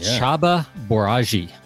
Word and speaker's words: Csaba Borzasi: Copywriter, Chaba Boraji, I think is how you say it. – Sounds right to me.